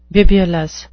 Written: bibulous

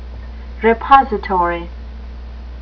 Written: repository

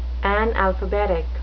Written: analphabetic

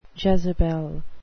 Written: Jezebel

